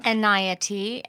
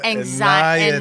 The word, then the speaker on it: Enayati: Enayati, and it rhymes with anxiety. – Oh. So that's how you know, now you know how to pronounce my name. – Amanda. – Enayati.